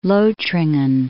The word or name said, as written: Lothringen